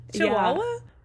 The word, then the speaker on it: Chihuahua: How do you say it? Chihuahua? Yeah, that was normal.